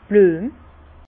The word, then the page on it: d' Blüem: Bas Rhin; Herrlisheim